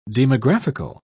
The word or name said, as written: demographical